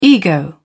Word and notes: ego: British English

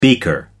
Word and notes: beaker: American English

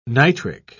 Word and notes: nitric: British English

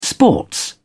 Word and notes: sports: British English

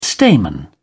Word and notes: stamen: British English

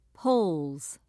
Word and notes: polls: British English

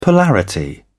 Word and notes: polarity: British English

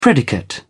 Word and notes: predicate: British English

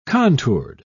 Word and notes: contoured: British English

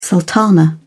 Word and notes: sultana: British English

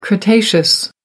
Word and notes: cretaceous: American English

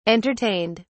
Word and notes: entertained: British English

